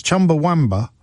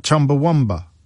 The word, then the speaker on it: Chumbawamba: I'm sorry to be that person, he writes, but it's Chumbawamba, not Chumbawamba. Who put the U in Wamba?